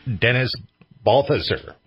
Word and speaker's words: Dennis Balthaser: All right, welcome back to Radio Wasteland, and we are here with our guest, Dennis Balthaser.